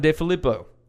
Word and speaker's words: DeFilippo: John DeFilippo. Yep. Did I say that right? – Yep. You said that right.